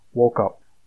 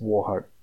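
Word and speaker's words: Wauchope: The town this club shares its name with, from the Sydney Morning Herald article in 1949, claims the town name to be Walkup, but I'm reliably told by Wikipedia it is pronounced Warhope.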